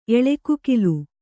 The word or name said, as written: ಎಳೆಕುಕಿಲು